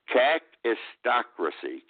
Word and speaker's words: kakistocracy: Kakistocracy. Kakistocracy. K-A-K. I-S-T-O-C-R-A-C-Y, kakistocracy.